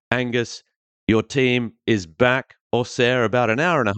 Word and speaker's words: Auxerre: Could Auxerre or Auxerre, as it's actually pronounced in France, and Angus, I know you lived there, you're an old Auxerre fan going way back.